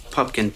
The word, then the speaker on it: Pupkin: Rupert Pupkin, P-U-P-K-I-N.